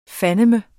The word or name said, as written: fandeme